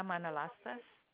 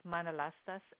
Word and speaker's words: Manalastas: My name is Myrna Manalastas. – May I have you spell that? – M-Y-R-N-A. And the last name is Manalastas, M-A-N-A-L-A-S-T-A-S. – That's M-Y-R-N-A, M-A-N-A-L-A-S-T-A-S.